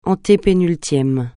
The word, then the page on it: antépénultième: francais